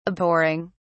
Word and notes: abhorring: British English

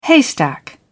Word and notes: haystack: British English

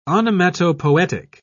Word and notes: onomatopoetic: British English

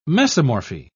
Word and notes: mesomorphy: British English

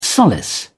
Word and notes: solace: British English

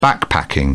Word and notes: backpacking: British English

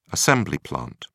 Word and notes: assembly plant: British English

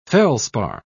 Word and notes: felspar: British English

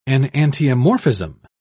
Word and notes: enantiomorphism: British English

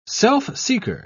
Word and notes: self-seeker: British English

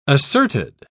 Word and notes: asserted: British English

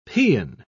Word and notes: paeon: British English

